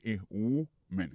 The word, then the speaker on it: woman: Prononciation, c'est a woman. Donc, ou, son ou. Monsieur, son woman.